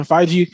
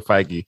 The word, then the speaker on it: Feige: Put the hard G in his name, man. – Feige? Feige. – All right. Gee. Soft G.